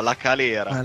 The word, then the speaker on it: La Calera: Della Balera? – No, La Calera.